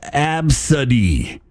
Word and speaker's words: ABCDE: And the name ABCDE, pronounced Absody.